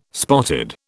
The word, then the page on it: spotted: British English